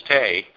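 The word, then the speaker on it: TE: but it's pronounced as tay in other countries all around the world.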